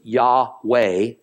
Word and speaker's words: Yahweh: The commonly held pronunciation is Yahweh or Yahweh, and this was later Englishized into the word Jehovah that we is commonly used in Christendom.